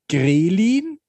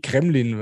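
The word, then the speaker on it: Ghrelin: Also, das heißt natürlich Grelin, aber ich konnte es nicht aussprechen, deswegen war mir irgendwie Kremlin, ja.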